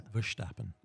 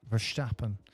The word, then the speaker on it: Verstappen: Verstappen. – Verstappen.